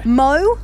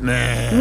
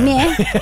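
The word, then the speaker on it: Mø: Mo or Meh. – It's pronounced Meh. – Meh.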